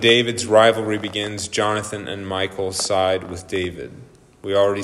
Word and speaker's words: Michal: Jonathan, and I've heard this pronounced different ways, Michal, because it's a girl, it's not Michael. Michal, wife of David.